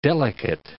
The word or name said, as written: delicate